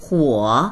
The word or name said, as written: huo3